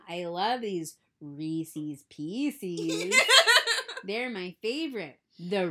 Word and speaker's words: Reese's: I know it's supposed to rhyme and it's supposed to rhyme with the word that I do know how to say. So it has to be Reese's.